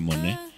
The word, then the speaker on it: Monáe: Es Janelle Monáe, se escribe Monáe, por cierto, M-O-N-A, la A acentuada, y E, pero se pronuncia Monáe.